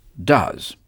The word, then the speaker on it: does: À la troisième personne du singulier, pour exprimer la négation, on ajoute un S à do, qui se prononce does.